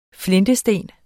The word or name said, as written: flintesten